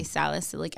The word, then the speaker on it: salicylic: Salicylic acid, if you're somebody like me who breaks out around their period or when they get stressed, to have a salicylic acid same.